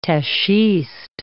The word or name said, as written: tachiste